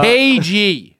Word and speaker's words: cagey: No, cagey. The word cage with a Y at the end. I clearly let you know that it was spelled like Nicolas Cage.